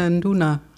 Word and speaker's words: Nduna: Nein, du hast es genau richtig ausgesprochen. Nduna heißt es.